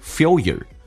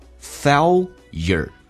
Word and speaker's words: failure: 来看一下第一个单词 failure，美式发音叫 fail， L 往前发 failure，英式发音叫 failure，它有点小小的区别。